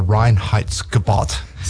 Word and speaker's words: Reinheitsgebot: And they even say they're adhering to the Reinheitsgebot. – So I was going to put you on the spot and say, what's that law they have over there? – Yeah. – And you pronounced it perfectly.